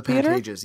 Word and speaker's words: Pantages: Pantages? – The Pantages, yeah.